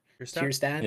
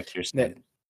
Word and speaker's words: Kjerstad: And I mean, how do you pronounce that guy's name you said earlier? Kjerstad? It's like K-J-E-R. – Kjerstad? – Yeah, Kjerstad.